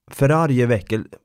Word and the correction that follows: förargelseväckande: Vad fan, det är svårt. – Förargelseväckande. – Där ja.